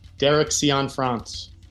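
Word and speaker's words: Derek Cianfrance: Derek Cian France.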